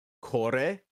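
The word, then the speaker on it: Kore (これ): Kore, pronounced ko-re.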